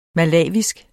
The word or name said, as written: malawisk